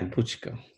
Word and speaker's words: Bucko: But I mean, I'm going to say your last name properly, as close to proper as I can. It's Bucko. – Yeah, Bucko.